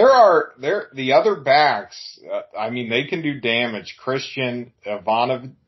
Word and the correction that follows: Ivanovic: Christian Ivanovic is a really, really good running back as well.